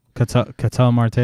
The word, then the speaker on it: Ketel: How do you say his name? Ketel or something? – Ketel Marte, yeah.